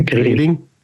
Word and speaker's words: Greding: Und ähm, ich bin gerade mal so ein bisschen, also wenn man Greding, Greiding spricht man es aus, glaube ich, ne? Greding, nee, Greding.